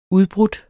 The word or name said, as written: udbrudt